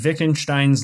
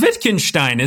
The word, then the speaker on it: Wittgenstein: It's Wittgenstein, isn't it? Wittgenstein. – Wittgenstein's ladder, I think is how you pronounce it.